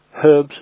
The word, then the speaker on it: herbs: Herbs is spelt H-E-R-B-S. And it is pronounced herbs. It is not pronounced herbs. There is no U in herbs. And also the H is pronounced herbs, not herbs.